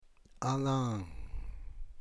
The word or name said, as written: aalang